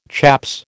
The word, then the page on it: chaps: American English